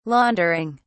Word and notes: laundering: British English

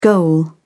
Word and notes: goal: British English

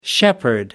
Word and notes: shepherdess: American English

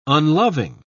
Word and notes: unloving: British English

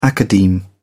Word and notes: academe: British English

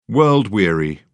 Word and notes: world-weary: British English

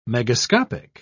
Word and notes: megascopic: British English

